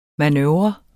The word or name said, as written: manøvre